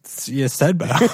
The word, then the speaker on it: Ydstebo: Yudstedbo or something, and his team name is... – No. That was definitely correct pronunciation. – Here's the spelling of his last name. Y-D-S-T-E-B-O. – Yeah. Yudstedbo.